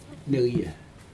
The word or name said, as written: necye